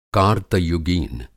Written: कार्तयुगीन

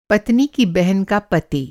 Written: पति

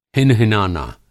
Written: हिनहिनाना

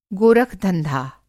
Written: गोरखधंधा

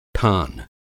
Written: ठान

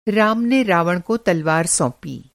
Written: तलवार

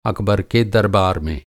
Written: दरबार

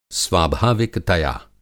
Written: स्वाभाविकतया

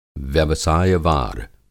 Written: व्यवसाय-वार

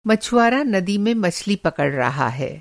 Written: मछली पकड़ना

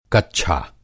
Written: कच्छा

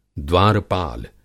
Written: द्वारपाल

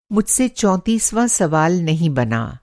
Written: चौंतीसवाँ

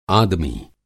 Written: आदमी

